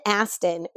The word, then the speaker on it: Aston: They were made up by a guy named Ken Aston, who ass is like there's an accent on the ass in this case.